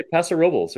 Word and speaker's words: Paso Robles: Locals say Paso Robles or just Paso.